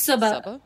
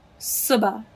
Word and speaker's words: Subhah: So the pronunciation is Subhah? – Subhah. I think, hold on, let me see. Subhah. Yeah, Subhah.